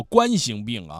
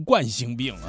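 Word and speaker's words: 冠心病: 海小杨说今天听磊哥的节目才明白读作冠星病啊，一直自己都读冠星病啊。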